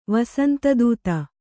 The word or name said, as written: ವಸಂತದೂತಿ